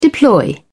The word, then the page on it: deploy: British English